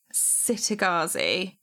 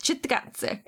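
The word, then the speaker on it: Cittàgazze: Okay, I'm going to spell it and then I'm going to say it because I feel like nobody knows how to pronounce it, and it's going to be like Tikai, but worse. So it's C-I-T-T-A with a little hat, G-A-Z-Z-E. As a kid reading it, I would always say Sitagazi or Kitagazi, I think. But we've heard people from the TV show pronounce it Chittagazi.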